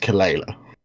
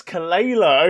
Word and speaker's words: Kelela: Somehow you managed to pronounce Sanguisugabogg fine, but you mispronounced Kelela. – Oh, it's Kelela.